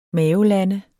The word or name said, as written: mavelande